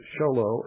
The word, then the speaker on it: Show Low: Folks, show low is spelled exactly like it sounds like it's spelled. It's two words, show and low. And yes, it's the same show and low that you learned in first grade.